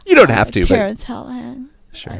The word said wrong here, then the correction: Hallinan: I, am not sure that's how to pronounce his name. – Hallen, Hallen, Hallinan.